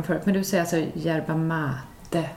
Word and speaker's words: yerba mate: Yerba mate. – Ja, just det. – Yerba mate. Yerba mate. – Faktaruta. Det uttalas lite försvenskat, yerba mate, och är som bekant en av professor Stigs favoritdrycker.